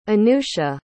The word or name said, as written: anusha